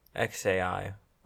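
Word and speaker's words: X Æ A-12: According to Grimes, the baby's name is pronounced XAI. Or just, it's just like X, like the letter X, then AI. But why does it have -12? XAI.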